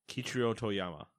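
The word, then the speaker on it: Keiichiro Toyama: Kichiro Toyama. – Kichiro Toyama.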